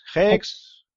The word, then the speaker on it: Hex: Es Hex. Bueno, en, inglés Hex, eh, si lo quieres decir en español, pues ex y ya está.